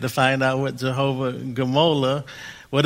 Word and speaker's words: Gmolah: This is one of the names of God, but it's the name Jehovah Gamola. Jehovah Gamola, and that's spelled G-M-O-L-A-H. Jehovah Gamola. Sounds like guacamole, but it's not. It's Jehovah Gamola.